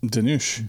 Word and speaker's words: Dhanush: Dhanush. – Dhanush.